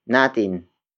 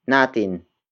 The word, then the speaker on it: natin: Word for word, it's nagising ang pusa natin. Nagising ang pusa natin.